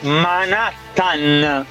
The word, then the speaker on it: Manhattan: Junior Jackson Spagno Spannaus che si dice Manhattan, non Manhattan.